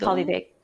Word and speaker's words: polyvagal: am I gonna say it wrong? Polynagal. – Polyvagal polyvagal theory. Polyvagal theory.